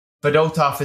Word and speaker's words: Fedotov: So anyway, Fedotov is how you pronounce it.